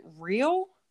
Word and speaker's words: Rehiel: It sounds like real, but like two syllables? – It's like a fishing reel with high in the middle. – Okay, I got it. But you don't pronounce the high. That's just the spelling.